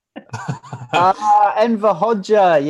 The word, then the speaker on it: Hoxha: Enver Hoxha, yes. – Hoxha, is that how you pronounce it? Yeah.